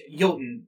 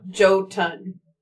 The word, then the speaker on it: Jotun: Oh, those were the same people who made Jotun, too. – Oh, yeah. By the way, it's Jotun. – Not Jotun. – Shit. – Jotun? – J-O-T-U-N is how it is spelled. Pronounced Jotun.